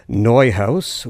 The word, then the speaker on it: Neuhaus: with percussion by Max Neuhaus, or Neuhaus would be the German pronunciation.